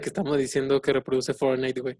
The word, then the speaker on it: Fortnite: El Frontside, ni nada de eso. – El Frontside. – ¿Qué es eso? – El Fortnite.